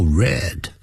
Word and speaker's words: read: He is well read. Well 是 W-E-L-L. Read 是过去分词 R-E-A-D. Read, 那个 read. 啊，念成 read 的时候呢，就是过去分词，它的拼法都是一样的。